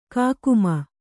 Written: ಕಾಕುಮನ

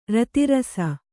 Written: ರತಿರಸ

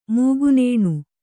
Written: ಮೂಗುನೇಣು

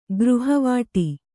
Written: ಗೃಹವಾಟಿ

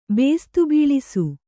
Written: ಬೇಸ್ತುಬೀಳಿಸು

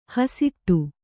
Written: ಹಸಿಟ್ಟು

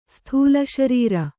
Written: ಸ್ಥೂಲಶರೀರಿ

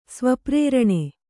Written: ಸ್ವಪ್ರೇರಣೆ

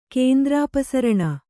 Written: ಕೇಂದ್ರಾಪಸರಣ

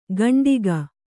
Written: ಕಂಟಿಗ

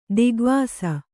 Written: ದಿಗ್ವಾಸ

